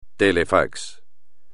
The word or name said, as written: telefax